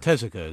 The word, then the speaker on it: Tezuka: And Tezuka is the correct pronunciation.